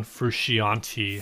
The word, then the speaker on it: Frusciante: then you're probably a John Frusciante fan. Is that how you say his last name? – John Frusciante.